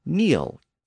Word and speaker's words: kneel: That includes words like need, K-N-E-A-D, and knee, K-N-E-E, and kneel, K-N-E-E-L, know, K-N-O-W, knife, K-N-I-F-E, knit, K-N-I-T, and knock, K-N-O-C-K. All of those words go back to Old English, and they were all once pronounced with an initial K sound.